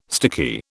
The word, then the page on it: sticky: British English